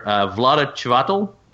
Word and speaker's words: Vlada Chvátal: Codenames is a 2015 game from Czech Games Edition by the prolific game designer Vlada Chvátal. Am I saying that right, guys? – Sounds right.